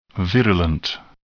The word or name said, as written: virulent